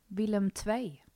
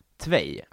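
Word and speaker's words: Willem II: vi kan ta upp uttalet på det här, hans nya lag. För det går ju särskilt. – Lär mig. – Willem Tvei. – Tvei. Ja, det är två då på holländska.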